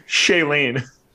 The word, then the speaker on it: Shailene: Aaron Rodgers and Shianne Woodley reportedly called off their engagement. I have no idea if I'm saying her name correctly, nor do I care. But, well, how do you say it? – Shailene.